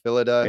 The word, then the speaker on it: Phyllida: Phyllida Law? Is that how you pronounce her name? – Yeah, I think so. Phyllida.